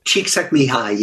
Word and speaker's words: Csikszentmihalyi: Oh, Csikszentmihalyi, yeah.